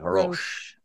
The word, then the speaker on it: Roche: So I say it that way, like Roche. – Roche. La Roche. – Yeah. – Yeah. – Roche. Yeah.